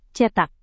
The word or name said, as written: cetak